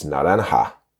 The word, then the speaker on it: naranja: In Spanish, if you speak Spanish, you know the word is naranja. The J, spelled with a J, but in Spanish, of course, that's pronounced naranja.